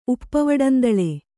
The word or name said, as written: ಉಪ್ಪವಡಂದಳೆ